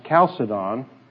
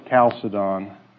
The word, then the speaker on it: Chalcedon: There's two ways it's pronounced. I've pronounced it Chalcedon, the Council of Chalcedon, but it's also been pronounced Chalcedon. So I made a very scholarly search. I went to the internet and typed it in, and it gave me two pronunciations. Both of them came up Chalcedon. So I'm going to try to remember to pronounce it that way, because I guess that's the official pronunciation.